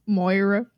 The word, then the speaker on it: Moira: Who is, how do you say her name? Moria? – I thought it was, like, Mora. Mora? Oh. – Moira. Yeah, yeah. I think Moiria? It might be Moira.